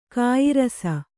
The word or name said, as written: ಗೇಯರಸ